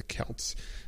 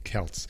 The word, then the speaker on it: Celts: How do you say Celts? Is it with a C sound or is it with a K sound? Sounds like Celts, okay. Celts, there it is, all right.